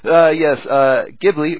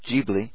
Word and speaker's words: Ghibli: Ghibli, or Ghibli, depending on how you like to pronounce it, will release Western animation in theaters and on DVD in Japan under a new Ghibli Museum Library label.